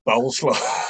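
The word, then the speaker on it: Bolesław: And the last one, which his Schmeichel's middle name, it's obviously not pronounced this way, but it's, if you can imagine the word coleslaw, but spelled with a B, Bowleslaw.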